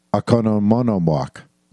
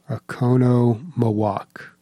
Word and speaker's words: Oconomowoc: Oconomowoc. – Oconomowoc.